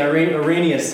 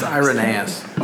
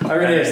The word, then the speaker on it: Irenaeus: Oh, we're saying Irenaeus said, I don't even know if I'm saying that right, but that guy said that he... – it's Irenaeus. – Irenaeus, thank you.